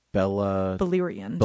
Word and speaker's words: Beleriand: Beleriand.